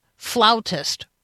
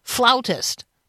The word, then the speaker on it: flautist: Flautist rhymes with aboutist. Aboutist is not a word. I pretty much know that for sure. But yes, I get it when you say rhymes with aboutist, that it is flautist.